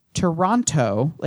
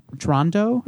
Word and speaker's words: Toronto: For example, the fact that if you pronounce Toronto, if you pronounce all of the T's in Toronto, it means that you're not from Toronto, because true Torontonians pronounce it, they pronounce it Toronto or something Toronto. – Like that. It's like a soft, like it's I almost like so. A, like the T's become D's or some shit.